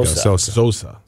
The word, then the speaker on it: Xhosa: That's how you would pronounce it. – There you go. – Xhosa.